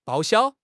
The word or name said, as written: 报销